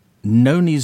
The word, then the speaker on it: Nony: Next, we have Noni Zero with You Fit. – We'll be right back. – from, I think, Totnes. That was Noni Zero, which is N-O-N-Y, pronounced No-Ni, Zero, with UFIT.